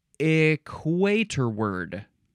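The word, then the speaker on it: equatorward: Equator word. Okay. It is spelled equator ward. This is one word, equator, and then W-A-R-D at the end, but it's pronounced equator word.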